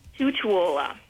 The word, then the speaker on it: Tutuola: Tutuola. – Spell that, please. – It is T-U-T-U-O-L-A.